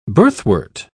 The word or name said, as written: birthwort